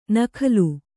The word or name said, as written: ನಕಲಿ